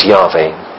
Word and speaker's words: Yahweh: Yes, Jehovah is the four Hebrew letters with the vowels of another name, Adonai, put in to the four. So probably the best pronunciation is Yahweh or Yahweh. That's probably the real pronunciation.